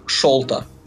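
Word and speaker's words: Шарлто: Я, я его всегда называл Шарлотта Колли, только сейчас увидел, что это П, а не Л. – А можно я вас, господа, исправлю? Его имя произносится как Шолта.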